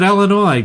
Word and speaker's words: Illinois: In Illinois, we pronounce it Illinois, damn it.